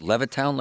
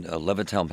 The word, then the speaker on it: Levittown: And this happened in, is it Levittown, Larry? Is that how you say it? – Yes. – Yep. This happened in Levittown, Pennsylvania.